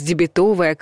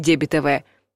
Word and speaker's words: дебетовая: И в кошельке у вас дебетовая карта, а не дебетовая. Да, ударение при образовании прилагательного ловко перемещается на суффикс.